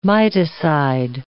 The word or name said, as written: miticide